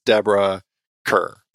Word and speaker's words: Deborah Kerr: And it is Deborah Kerr.